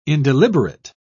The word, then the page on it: indeliberate: British English